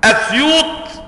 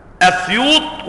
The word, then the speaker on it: أسيوط: والذي تحرر لي بعد مراجعة كتب اللغة ومعاجم البلدان ومجاميع الحفاظ والأدباء وغيرهم أن في سيوط 5 لغات: أسيوط وأسيوط بالضم وبالفتح، أسيوط وأسيوط بالفتح وبالضم. أما سيوط فمثلثة: سيوط وسيوط وسيوط، مثلثة، السين تنطق بالفتح وبالكسر وبالضم.